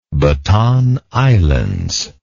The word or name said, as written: Batan Islands